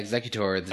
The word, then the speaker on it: Exeggutor: Then the other Pokemon thing that happened was those giant ass Exeggutor. – Yeah. – Am I saying that right? – Yeah, Exeggutor, the giant palm tree versions.